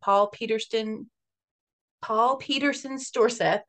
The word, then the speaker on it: Storseth: Belle Gunness was actually born by the name Brynhild Paulsdatter Storthus. Storseth? Didn't say that right. – I'll try it again. Belle was actually born by the name Brynhild Paulsdatter Storseth on November 22nd, 1859, in Selbu, Norway.